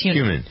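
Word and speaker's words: Hunnam: Is that how you pronounce his name? Heumann? – Yes, Heumann.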